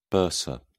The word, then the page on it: bursa: British English